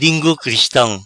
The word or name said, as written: Lingu Cristam